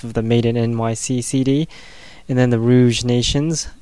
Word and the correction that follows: Rogue Nations: actually it's the Rogue Nations, I keep pronouncing that band name wrong for some odd reason, yeah it's the Rogue Nations, not the Rouge, Rogue Nations with Taking Up Arms, it's the vinyl version, and Anti-Flag with A New Kind of Army, also vinyl before that.